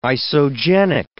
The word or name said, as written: isogenic